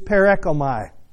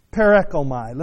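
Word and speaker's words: parechomai: That word "pass away" in the Greek is perekomai. Perekomai. Let me just spell it for you if you're taking notes. P-A-R-E-C-H-O-M-A-I. P-A-R-E-C-H-O-M-A-I.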